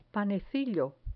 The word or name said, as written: panecillo